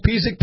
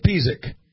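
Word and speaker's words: Pisek: Somebody's asking how to pronounce Pizik. You pronounce Pizik, Pizik. Pizik is in the eastern end of North Dakota.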